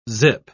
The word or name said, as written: zip